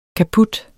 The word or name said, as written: kaput